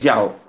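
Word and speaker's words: Yeoh: Como yo sé que tú eh, tienes mucha experiencia en, el cine asiático, eh, la actriz Michelle, ¿se, pronuncia Yao o, o Yeo? – Eh, Yao, le dicen Yao. – Michelle, Yao, sí, correcto.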